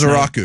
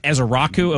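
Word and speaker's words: Ezeiruaku: And Donovan Ezekiereku, I believe is how he pronounces his last Azaraku. – Name. Is it Azaraku? Okay.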